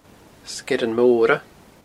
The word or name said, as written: Sgeirean Mora